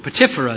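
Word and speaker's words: Potipherah: Potipharah is actually the way that's pronounced.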